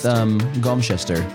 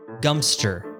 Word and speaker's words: Godmanchester: Also incorrect. What looks like God followed by the word Manchester is actually pronounced Gumster. – Gumster! – Whoa! – So I went extreme of shrinking it, and it should have been shrunk even more. – Yes. – Which rhymes with dumpster.